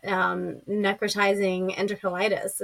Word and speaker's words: necrotizing enterocolitis: because that it can help prevent necrotizing enterocolitis.